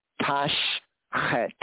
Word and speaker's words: tashchet: They kind of blur the S-C-H there. More better, they could have pronounced or transliterated as A-L for not, al, and T-A-S-H, right? And then we have a space, and then we have chet. So it's sh-chet. Tashchet, tashchet, which means do not destroy.